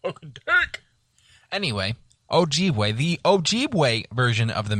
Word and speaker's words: Ojibwe: That also includes the, I'm probably going to murder this. – Ojibwe. – I'm going to say that's probably the best pronunciation.